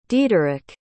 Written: diederik

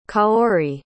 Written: kaori

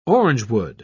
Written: orangewood

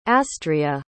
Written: astrea

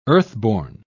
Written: earthborn

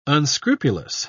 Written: unscrupulous

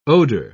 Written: odor

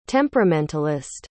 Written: temperamentalist

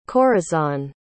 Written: corazón

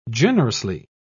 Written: generously

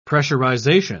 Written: pressurization

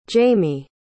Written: jaymie

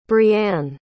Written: breann

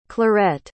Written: clarette

